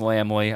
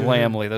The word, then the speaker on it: Laemmle: By the way, absolutely pronounced Laemmle. I finally took the fucking time to look it up. It is Laemmle.